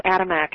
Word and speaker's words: Adamek: Yeah, it's Adamek. It's unfortunately three syllables and six letters, so it's a mouthful.